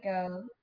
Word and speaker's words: Geaux: Yes, here we go. Go is spelled GEAUX, the French way.